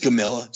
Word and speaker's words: Gomila: I'll butcher your last name, Gamilla? – Just Gamilla. Just Gamilla. Straight phonetics.